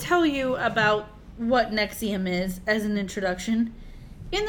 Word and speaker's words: NXIVM: The topic is Nexium, but it's spelled all caps NXIVM.